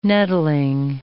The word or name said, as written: nettling